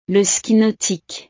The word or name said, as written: le ski nautique